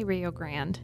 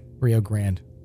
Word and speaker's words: Rio Grande: Up north, I think we say Grande. – We say Rio Grande. – Okay, Rio Grande.